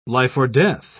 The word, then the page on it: life-or-death: British English